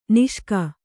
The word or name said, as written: ನಾಶಕ